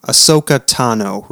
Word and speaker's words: Ahsoka Tano: The reveal of his name came with a very detailed backstory given by, and help me if I'm pronouncing this wrong, Ahsoka Tano, right? – Perfect.